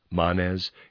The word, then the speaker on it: Manes: Readers aside, I'm pronouncing that Manes. It's M-A-N-E-S. It might be Manes, but that just doesn't sound right to me.